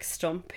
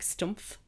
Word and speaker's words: Stumpf: He dropped the H because it's hard to pronounce. So I presume it probably is just pronounced Stump, but I just enjoyed that there was a H at the end and now there's not.